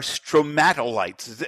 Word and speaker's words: stromatolites: What are stromatolites? Am I pronouncing that correctly? – Yes. Yep.